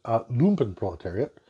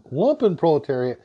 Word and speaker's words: Lumpenproletariat: So in German, it's pronounced lumpenproletariat, but somehow in English, the accepted pronunciation has become lumpenproletariat, so I'm going to try to stick with that, even though it's clearly wrong.